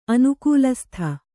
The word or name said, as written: ಅನುಕೂಲಸ್ಥೆ